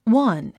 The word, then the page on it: one: wʌ’n; ワン